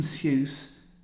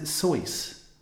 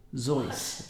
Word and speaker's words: Seuss: If you're calling him Seuss, he pronounces it Soyce, or Zoyce, apparently. Apparently, Dr. Seuss, as I always thought he was, apparently is Dr. Soyce."